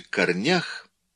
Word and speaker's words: корнях: И даже кто-то поправил его, заметив, что ударение в слове «корнях» падает на «я», а не на «о».